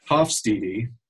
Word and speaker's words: Hofstede: I said his name wrong, I think 10 different ways in the podcast. Hofstede. So it's GeertHofstede.